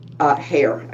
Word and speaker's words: Heyer: Heyer, I know that's how you pronounce it.